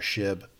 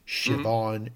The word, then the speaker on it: Shiv: We get a lot of feedback from our fans who say that I mispronounce Shiv's name early on. I called her Shib, Shivon.